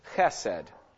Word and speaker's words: chesed: But the word here in Hosea 6:6, I desire, the Hebrew word is chesed, or chesed if you want to say it without the Hebrew ch at the beginning, chesed.